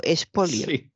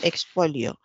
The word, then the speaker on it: expolio: Lo que pasa es que como casi nadie pronuncia bien la X, entre que ella dijo espolio y no expolio.